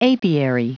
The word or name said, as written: apiary